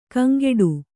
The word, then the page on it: ಕಂಗೆಡು: kaŋgeḍu